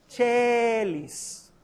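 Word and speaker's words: caelis: Recuérdense pronunciar las letras, no lo que tú oiga Pater noster qui, no qui, qui es in chelis Con la E abierta, Chelis ¿Está bien?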